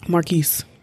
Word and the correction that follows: Marques: We Marquess. – That's how it's spelled.